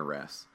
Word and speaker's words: wrasse: Yeah, so the next one then is cleaner fish, or the cleaner wrasse.